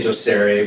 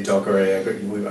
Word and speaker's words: Docere: Well, it's a Latin word, so I think you can pronounce it however you want. We say Docere, but people say Docere. I've heard everything. – I was thinking it was like Spanish. It's D-O-C-E-R-E, Docere.